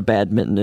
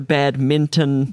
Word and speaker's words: badminton: And the pronunciation of badminton is so baffling. – Yeah. – I mean, it's really a hard word to... You're tempted to do the N and the T, the badminton. – Badminton, yeah.